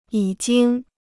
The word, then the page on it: 已经: yǐ jīng